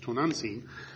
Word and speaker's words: Tonantzin: They've assimilated the ancient worship of Tonantzin, or Tonantzin, that's how it's supposed to be pronounced, Tonantzin, this goddess of these Mayan Indians, or these Aztec Indians, and they've turned it into a Christian type.